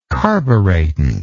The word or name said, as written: carburetant